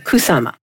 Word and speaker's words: Kusama: So for example, right now we have still up a wonderful infinity room by a Japanese artist, Yayoi, as she pronounces it, Kusama.